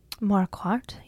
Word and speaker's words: Marquardt: Am I saying that right? – Marquardt? Yes, I think you are.